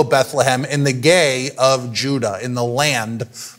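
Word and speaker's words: gay (γῆ): The word earth here in the Greek is pronounced gay. Um, that's just what the Greek word is.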